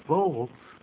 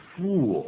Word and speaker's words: full: No thanks, I'm full, 我吃饱了。可是念这个字的发音可要小心，不要念成 fool, fool 就是傻瓜的意思。 – 是傻子，那是 F-O-O-L, F-O-O-L, 而这边是用 S-U-L-L, 请 Karen 再给我们念一下。